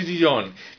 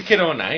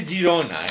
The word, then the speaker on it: Girona: Bueno, y usted, que se escucha usted, que no se dice Girón, que se dice Gerona, ¿eh? Se dice Girona, ¿eh? – Ah, yo es que soy de Madrid.